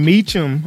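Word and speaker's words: Mecham: However, just before the holiday in 87, then Governor Evan Meacham, I believe it's pronounced, made it his first act to rescind the proclamation that he argued was created illegally by Babbitt.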